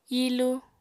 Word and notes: hilu: Apatani

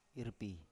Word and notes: irpi: Biak

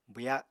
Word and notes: bwya: apatani

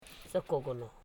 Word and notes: sokogolo: so.ko.go.lo

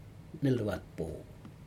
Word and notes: nelwatpo: Aneityum

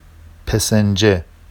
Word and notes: pesenʤe: Vafsi

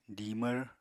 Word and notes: dimvr: Apatani

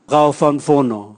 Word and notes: gaovanvono: Raga

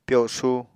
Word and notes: pyosu: Apatani

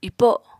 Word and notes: ipo: apatani

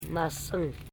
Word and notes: masing: ma.sing

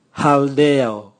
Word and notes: haldeo: Raga

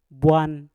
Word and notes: bwan: Mpur

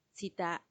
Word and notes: tsitaa: Macuiltianguis

